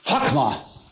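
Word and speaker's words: Chachma (חכמה): K-N-A-Y. Kane. What does it mean? Get. That's all it means. Get. Chachma. That's the C-H sound, hard C-H. Chachma, which means wisdom. – C-H-M-E-H?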